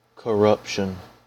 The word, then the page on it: corruption: American English